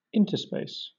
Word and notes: interspace: British English

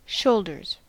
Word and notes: shoulders: American English

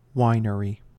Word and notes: winery: American English

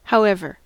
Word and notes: however: American English

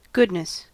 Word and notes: goodness: American English

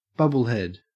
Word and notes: bubblehead: Australian English

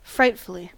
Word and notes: frightfully: American English

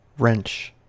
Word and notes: wrench: American English

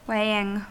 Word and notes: weighing: American English

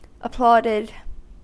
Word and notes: applauded: American English

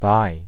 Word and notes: bi: American English

